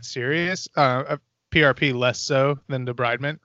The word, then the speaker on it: debridement: It could be debridement as well, because sometimes, like, is that how you say it, debridement? – Yep.